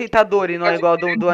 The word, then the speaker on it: Itadori: O I que eles puxam no anime é o primeiro, não é o último. E eu falo Itadori, normal.